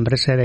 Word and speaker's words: pretzel: a ver si lo pronuncio bien, pretzel. – Pretzel, sí, eso es.